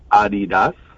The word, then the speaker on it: Adidas: But it's actually pronounced Adidas. – Adidas? – Adidas. – Adidas? – Adidas is actually Adidas.